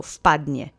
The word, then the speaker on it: впадне: And do notice that although it starts with a V, we're going to shorten it in speech. It sounds like F, so впадне. Let's say впадне together three times. Accent is on the first syllable.